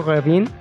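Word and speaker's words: Grévin: Me estoy hablando específicamente del Museo Grevin O tú que tienes apellido francés, ¿cómo se dice? Museo Revin. Revin. Revin.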